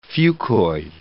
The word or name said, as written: fucoid